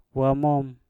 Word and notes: wamom: mpur

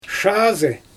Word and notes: ʃaazɯ: Xyzyl

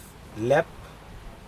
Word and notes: lep: Aneityum

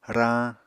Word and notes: rañ: Apatani

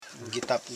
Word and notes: gitap: gi.tap

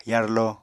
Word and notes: yarlo: apatani

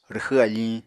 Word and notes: rwxw afiñ: Apatani

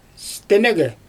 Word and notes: stɛnɯgɛ: Xyzyl

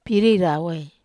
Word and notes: pirie rawai: wamesa

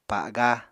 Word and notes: pa7ga: Apatani

